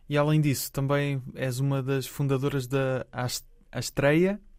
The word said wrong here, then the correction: Astraia: Disse bem? – Astraia, exatamente. Astraia. Ah, foi a pronúncia falhou ali no final.